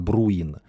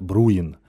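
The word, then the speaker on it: Bruhin: По-немецки его фамилия произносится как Бруин, но швейцарцы говорят Бруин.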